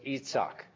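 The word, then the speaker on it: Yitzhak: We say Isaac. The British say Isaac. Everybody else says Itzhak. That's how the name would be pronounced.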